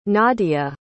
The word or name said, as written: nadiya